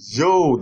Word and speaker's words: Zhou: In China, we have the most powerful king of the Zhou Dynasty. Our Chinese listeners have complained. It's not pronounced the Su Dynasty, but the Zhou Dynasty.